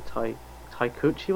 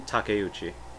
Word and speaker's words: Takeuchi: Taikuchi, was that something? – Takeuchi.